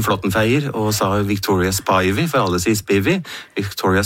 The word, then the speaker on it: Spivey: Det Spivey, ja, og jeg var jo på en lengre researchtur I USA og intervjuet folk, Og da ble det uttalt Spivey. Ja, så Bruce Ziegler I Alligator Records, for eksempel, sier Spivey, Spivey Records. Ja. På Bluesarkivet I Mississippi sa de også Spivey.